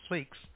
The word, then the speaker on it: Fix: The first name is Sam, S-A-M, last name is Fix. How you spell that? F-I-X-S? – Just one X.